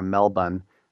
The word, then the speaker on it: Melbourne: Melbourne, I saw in Australia, I remember never pronounced the R, Melbourne.